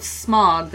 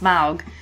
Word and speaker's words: Smaug: I did read something where someone today was like, I thought it was pronounced Smaug, but I guess it's not. And then someone else had, and they said they looked it up in the appendices, and I guess he has Tolkien has a pronunciation guide, so it should be more Smaug.